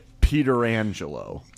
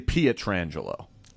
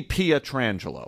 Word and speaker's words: Pietrangelo: His name is Petrangelo. – You could possibly say Pietrangelo. There's an IE in there. I can see Pietrangelo or it's PIE.